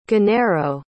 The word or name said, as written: genaro